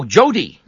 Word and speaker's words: Joddy: Oh, Jody. – Yeah, J-O-D-D-Y.